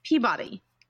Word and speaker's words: Peabody: It's spelled P-E-A-B-O-D-Y. – Peabody. – That's cute. No. It's Peabody.